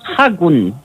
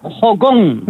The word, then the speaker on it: Haakon: A ver, el actual rey, uh-huh, escrito H-A-A-K-O-N, Hakon, ¿no? – No se pronuncia Hakon. – ¿No? ¿Cómo es? – Se pronuncia Hakon. – Venga ya, ¿sí? – Al revés, claro.